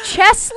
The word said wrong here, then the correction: Chelsea: Is it Chessie? – It's Chelsea.